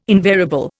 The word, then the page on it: invariable: Indian